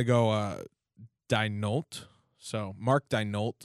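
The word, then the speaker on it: Daigneault: I believe it's Mark Dignault. I think the G is silent.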